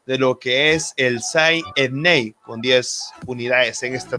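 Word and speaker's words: Saint-Étienne: De hecho, si es complicado mencionar en inglés, pues ya va a ser en francés, pero es Saint Etienne. Es la pronunciación correcta, Osvaldo.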